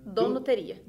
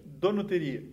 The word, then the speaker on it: Donuteria: É, como que pronuncia certo? – Donuteria. – Donuteria. – Isso.